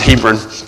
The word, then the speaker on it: Hebron: I'm from Hebron, Indiana. So, we pronounce it Hebron.